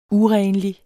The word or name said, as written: urenlig